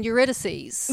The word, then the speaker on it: Eurydice: He's talking about Orpheus and Eurydice.